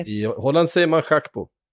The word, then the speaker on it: Gakpo: Han är holländare. Det ska vara Schackpo.